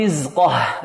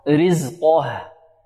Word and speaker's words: رزقه: يحذر الطالب أن يفخم الهاء لمجاورتها القاف فيقف عليها كذا، علي فقدر عليه رزقه، رزقه خطأ، رزقه رزقه. وكذلك يحذر من ترقيق القاف أن ينطقها مرققة هكذا، رزقه، فقدر عليه رزقه، أو لا يفخمها تفخيما جيدا، رزقه، لا يفخم القاف ويرقق ماذا؟ الهاء، فقدر عليه رزقه، رزقه.